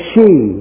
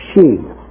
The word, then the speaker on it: sidhe: The people of the goddess Dana, Tuatha De Danann, or the she, the way you pronounce S-I-D-H-E, she.